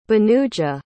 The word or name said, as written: bhanuja